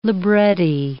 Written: libretti

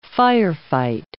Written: firefight

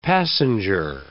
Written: passenger

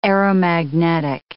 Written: aeromagnetic

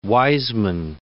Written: Wiseman

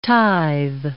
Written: tithe

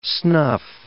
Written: snuff

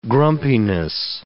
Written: grumpiness

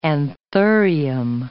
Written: anthurium

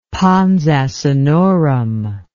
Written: pons asinorum

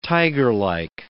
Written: tigerlike

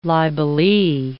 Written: libellee

